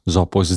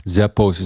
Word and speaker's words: Zappos: Zappos is denk ik de Nederlandse uitspraak. Zappos is de Engelse uitspraak.